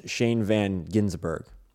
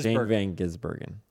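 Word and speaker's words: Shane van Gisbergen: So I thought all weekend, I thought it was Shane Van Ginsburg, which is not it.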